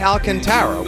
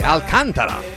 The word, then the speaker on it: Alcantara: I was today years old when I realized all this time I've been mispronouncing his name. Here I've been like a common baseball plebeian saying Sandy Alcantara, when of course I should be saying Sandy Alcantara.